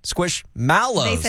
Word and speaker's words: Squishmallow: They had at one time some meal promotion with Squish, what they said, Squishmallows. – They said Squishmallow in their commercial. – And it was not mellow.